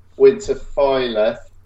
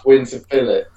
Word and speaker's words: Winterfylleth: A lot of people say Winterfylleth. – Yeah. – That's actually wrong. – Right. – It's pronounced Winterfylleth. But it's, I know, I understand why people pronounce the Fylleth. But yeah, it's Fylleth.